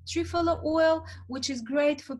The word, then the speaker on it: Triphala: Trifala oil, T-R-I-P-H-A-L-A. – Fantastic. Thank you. – Yeah, trifala oil.